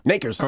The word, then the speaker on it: Nakers: Nakers. – All right? Kinda. No. All right? Nakers rhymes with Lakers, Takers, Bakers, Quakers, Fakers, Shakers, Makers.